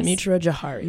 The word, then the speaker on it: Mitra Jouhari: Mitra Jouhari. Can I say it right? – Yes. – Mitra Jouhari.